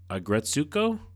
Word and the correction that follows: Retsuko: The character's name is Retsuko.